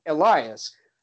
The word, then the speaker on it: Elias: For some of these names in the NHL, I look at the name Elias and I think, oh, that looks like Elias. But you say this in Swedish, it comes off like the opposite you would pronounce it. So it comes off as Elias.